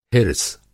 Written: हिर्स

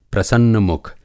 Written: प्रसन्न-मुख

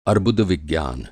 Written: अर्बुदविज्ञान

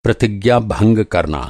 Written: प्रतिज्ञा

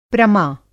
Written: प्रमा

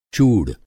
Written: चूड़

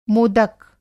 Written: मोदक

